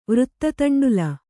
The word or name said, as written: ವೃತ್ತತಂಡುಲ